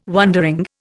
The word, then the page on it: wondering: Indian